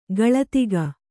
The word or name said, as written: ಗಳತಿಗ